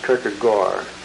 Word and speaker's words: Kierkegaard: The pronunciation is Kierkegaard. Can anybody correct me on that? That's what I've been told. I used to first pronounce as Kierkegaard, like a Dutchman would. but it isn't that. It's Kierkegaard, I'm told.